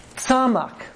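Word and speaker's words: tzamak (צמח): It's tzamak. Tzamak. This is tzade again. T-S, right? And an M, and then a C-H, like Bach.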